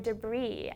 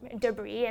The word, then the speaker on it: debris: debris, and my classmate pronounced it debris, and I was like, that's ridiculous, it's Debris. It's, don't you see the S?